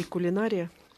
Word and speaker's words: кулинария: И кулинария? – Что касается кулинарии, то я говорю кулинария.